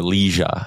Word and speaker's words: Liga: Yeah, he's been playing in the Liga for a handful of years, or Liga, I think is the right way to say it.